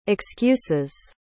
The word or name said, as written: excuses